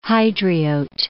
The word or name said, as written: Hydriote